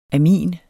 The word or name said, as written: amin